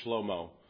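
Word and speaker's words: Shlomo (שלמה): If you think about it, really, you could, and if you take the Hebrew pronunciation of his name, Shlomo is how it's got.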